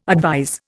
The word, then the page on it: advise: Indian